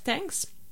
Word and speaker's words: TNKS: donc qui ça s'écrit T-N-K-S, mais ça se prononce Thanks.